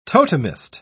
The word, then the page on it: totemist: British English